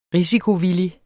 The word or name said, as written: risikovillig